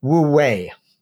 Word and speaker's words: wu wei: You can, I think this is quite central to your argument, woo-wee and you-wee. – Right. – So how am I supposed to pronounce that? – Good try, Jim. But it's generally pronounced something like woo-wee.